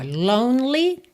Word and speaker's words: lonely: Então, ó, lonely, não pronuncia o E do meio. People, sempre importante verificar a pronúncia dessa palavra, né? Que se escreve P-E-O-P-L-E, e não tem nada de people, tá?